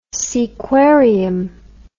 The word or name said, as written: Seaquarium